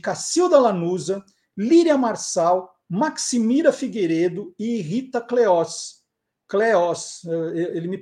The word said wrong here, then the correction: Cleos: pegou lá aqui, eu falei errado, né? Cleos. Ai, Maga, me desculpa, é Cleos.